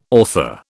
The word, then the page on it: author: British English